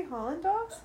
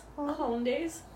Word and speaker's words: Hollandaise: Did you say Holland dogs? – Hollandaise.